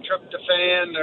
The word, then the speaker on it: tryptophan: The little chemical in turkey, the tryptophan or whatever it's called.